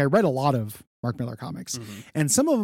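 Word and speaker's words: Mark Millar: I have a complicated relationship with Mark Miller, mm-hmm. – is actually, I think, how you're supposed to pronounce it.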